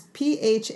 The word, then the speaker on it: Phaidon: it's from the publisher, do you say Phaidon? – I don't know how to pronounce that word correctly. – It's Phaidon.